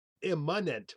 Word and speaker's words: immanent: What does immanent mean? I-M-M-A-N-E-N-T. Immanent. I'm overpronouncing it. – I-M-M-A-N-E-N-T? – Yes.